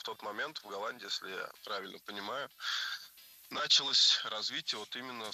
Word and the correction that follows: началось: Началось неверно. Вот здесь уже ударным становится окончание. Началось. Дело в том, что возвратная часть «се» во многих словах как бы притягивает ударение к себе.